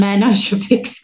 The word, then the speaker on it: Manogepix: How did you pronounce it? Manoji Pix, the drug? – It's picks. – Manogepix because I see it in papers all the time and I never know how to say it.